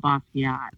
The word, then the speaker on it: Basquiat: Basquiat? What, you put it in a basket? Is that what you said? – You work at this museum and you don't even know how to pronounce it? Jean-Michel Basquiat.